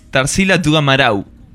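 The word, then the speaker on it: Tarsila do Amaral: Sí, en realidad la pronunciación correcta sería Tarsila du Amaral. Termina como en una U.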